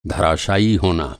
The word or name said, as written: धराशायी